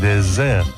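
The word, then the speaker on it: désert: En francés, postre es dessert y desierto es Desert. La diferencia consiste en que desert, desierto, se pronuncia con una S sonora,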